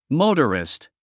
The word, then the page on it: motorist: móutərist; モゥタリスト